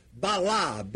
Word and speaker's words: bala: The word husband in Estradgilo Aramaic is the word bala, B-A-L-A, long A, first A.